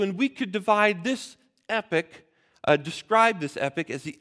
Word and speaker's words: epoch: I'll use James Dunn's word, two epics. Not epic, E-P-I-C, like an epic tale or story. E-P-O-C-H. In American English, we pronounce them the same way, I think.